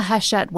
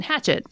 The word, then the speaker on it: Hachette: So if you order from the Hachette website, so it's pronounced Hachette, but it's spelled like hatchet with two E's and an E, Hachette. So you've probably seen it and said hatchet, right?